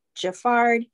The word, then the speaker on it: Geffrard: Jaffard. So last name, G-E-F-F-R-A-R-D.